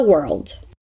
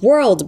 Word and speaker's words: world: Repeat, soon you'll move up in the world. We've worked on the pronunciation of world before, remember? Let's repeat it, elongating the word so it's easier to say.